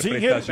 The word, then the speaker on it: Singer: La época era la Singer. – Y la pronunciación oficial debía ser Singer. – Sí, claro. – Sí. Singer. Sí, es que sí era. Sí. Pero decíamos Singer siempre en los montañeritos.